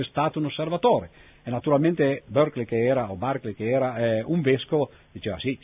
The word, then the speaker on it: Berkeley: Molti di voi avranno sentito parlare, per esempio, a scuola del vescovo Berkeley, o come si dice adesso all'americana, Berkeley, che però naturalmente è una pronuncia sbagliata.